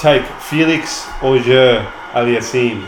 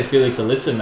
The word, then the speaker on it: Félix Auger-Aliassime: Félix Auger-Aliassime. – How do you know how to pronounce these guys' names? You're good. I just say Félix Alyssa, mate.